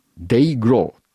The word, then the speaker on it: DYGL: DYGL、 Day Glow という風に発音するらしいんですが、まあ本当に息のいいロックンロールバンドっていう感じのアーティストですね。